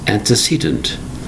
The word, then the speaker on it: antecedent: Today's word is antecedent. Antecedent. Four distinct syllables spelled A-N-T-E-C-E-D-E-N-T. Antecedent.